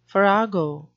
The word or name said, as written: farrago